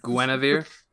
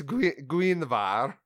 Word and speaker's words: Guenhwyvar: Is that really how it's pronounced? Like, you know this? – Yeah, Guinevere. I, I'm not gonna argue. – Guinevere. – Guinevere. Right. sorry. Guinevere. – Sorry. – That's his second ability. It's Guinevar.